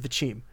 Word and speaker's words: Wyzim: It's spelled W-Y-Z-I-M, but apparently it's pronounced Vichim.